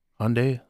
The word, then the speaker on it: Hyundai: And it's like, is it Hyundai? And he's like, Hyundai like Sunday.